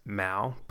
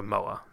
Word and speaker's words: Moa: I've heard people pronounce it as Mao, but... according to the wiki, her last name was selected because of a New Zealand bird called a moa. So, it is Moa.